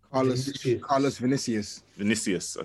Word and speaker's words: Carlos Vinicius: I don't know how do you pronounce this? – Carlos Vinicius. – Okay, yeah. So Carlos Vinicius.